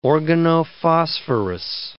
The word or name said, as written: organophosphorus